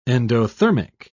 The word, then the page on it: endothermic: British English